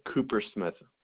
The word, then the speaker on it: Coopersmith: Ted Coopersmith. – Thank you. Could you spell that last name for me, please? – C-O-O-P-E-R-S-M-I-T-H.